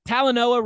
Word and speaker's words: Talanoa: Talanoa, right? That's how he pronounces his first name.